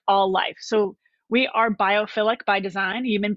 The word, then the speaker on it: biophilic: I wanted to hit on what you just said, the biophilic design.